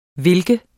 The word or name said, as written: hvilken